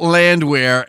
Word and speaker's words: Landwehr: Paul Landwehr, and the pronunciation of your name is correct, but the spelling is L-A-N-D-W-E-H-R.